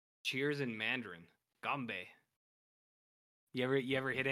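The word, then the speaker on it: ganbei (干杯): What's Cheers uh, in Mandarin is uh, pronounced ganbei.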